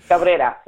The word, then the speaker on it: Cabrera: I say Cabrera, but that's not how you say it. You got to roll your tongue like you do.